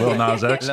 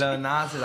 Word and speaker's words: Lil Nas X: Lil Nas X. – Lil Nas X.